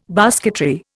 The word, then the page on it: basketry: Indian